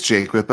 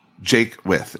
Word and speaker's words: Jaquith: It's Jaquith, but nobody gets it right. And I Jaquith. Love you so much. I don't care how much you say my name. – So is Jaquith? – Jaquith. It's originally a French word.